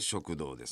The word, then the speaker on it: 食道: 食堂の漢字は、食の道と書いて食堂ですね。